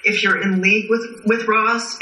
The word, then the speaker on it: Ra's: Yeah, it's Ra's. It should be Ra's. Because it is, in fact, Arabic. And that's the pronunciation.